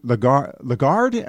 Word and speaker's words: Lagarde: I mentioned that Christine Lagarde, am I correct in pronouncing her last name? – Yes.